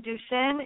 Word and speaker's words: Duchenne: I called it Duchenne, like the school here in Omaha, but the proper way maybe to pronounce that. Just a little bit about that particular disease, and I alluded to the prognosis for the boys, but time-wise, and then what it is that you're in such desperate need for. – Okay, yeah, you are pronouncing it right. It's Duchenne muscular dystrophy, and Duchenne is one of the 43 muscular dystrophies that is diagnosed in early childhood.